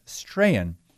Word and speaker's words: Strachan: Owen Strahan, his last name is spelled S-T-R-A-C-H-A-N, but I believe it's pronounced Strahan. And I have a quote from him,